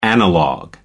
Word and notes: analog: British English

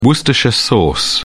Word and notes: worcestershire sauce: British English

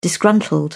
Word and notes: disgruntled: British English

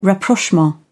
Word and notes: rapprochement: British English

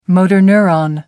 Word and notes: motor neuron: British English

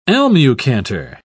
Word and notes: almucantar: British English

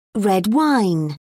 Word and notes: red wine: British English

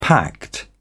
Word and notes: pact: British English

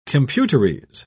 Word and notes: computerese: British English

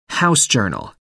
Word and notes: house organ: American English

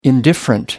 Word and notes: indifferent: American English

